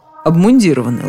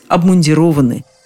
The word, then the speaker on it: обмундированы: Как правильно сказать, солдаты обмундированы или обмундированы? Откуда такой вопрос, понятно. Мундир значит обмундированы. Логично? Вполне. Однако в данном случае это порочная логика. Ударение из корня уходит. Обмундировать, обмундированный, обмундироваться, обмундирование. Надо сказать, что словари в этом единодушны, то есть никаких вариантов они не предлагают. Солдаты должны быть обмундированы.